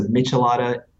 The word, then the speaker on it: Michelada: So Michelada, forgive my pronunciation, those of you who speak Spanish and speak it well.